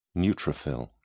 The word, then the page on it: neutrophil: British English